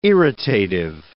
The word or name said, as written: irritative